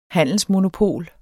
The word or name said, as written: handelsmonopol